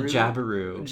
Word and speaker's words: Jabiru: So they go on a yacht called the how did you say this? The Jabiru. – The Jabberoo. Jabberoo. it's called the Jabberoo, which is like the most like inelegant stork it's a type of yeah.